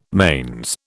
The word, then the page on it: manes: British English